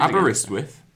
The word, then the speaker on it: Aberystwyth: Aberystwyth.